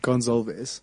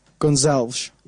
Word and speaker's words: Gonzalez: I pronounce it Gonzalez. – And then if you want to add a little Portuguese accent to it, how would it be? – I never do this. You would say... Gonzalez.